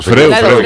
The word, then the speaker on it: Freud: Pero se escribe Freud, Freud o Freud. Freud, como Sigmund Freud.